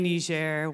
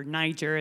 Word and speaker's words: Niger: So fast forward, I'm in Peace Corps training, and I land over there in Niamey, Niger, West Africa, or Niger, as Americans like to pronounce it.